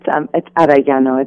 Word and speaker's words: Arellano: It's Arellano. It's a Spanish pronunciation.